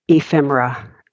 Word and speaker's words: ephemera: How do you say it? Ephemera? – Ephemera. Ephemera. E-P-H-E-M-E-R-A.